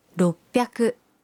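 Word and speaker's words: roppyaku (ろっぴゃく): 600 se convierte en roppyaku.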